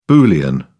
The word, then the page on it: boolean: British English